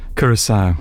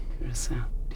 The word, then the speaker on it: Curaçao: And how do I pronounce the C-U-R-A-C? – Curaçao. – Okay, Curaçao.